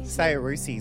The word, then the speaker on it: Saoirse: Saoirse Ronan. Saoirse. – No, okay. Saoirse is good.